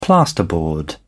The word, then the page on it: plasterboard: British English